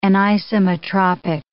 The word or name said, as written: anisometropic